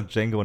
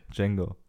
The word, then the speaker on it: Django: The D ist silent. Nicht G. – Django.